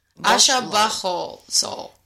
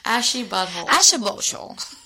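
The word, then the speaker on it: Asha Bhosle: B-H-O-S-L-E. Aisha Bosole. – No, that sounds like a butthole. No. Aisha Bosole. Aisha Bosole.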